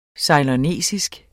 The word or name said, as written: ceylonesisk